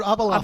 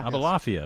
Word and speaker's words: Abulafia: Richard Abulafia. – Abulafia. – Abulafia, of course.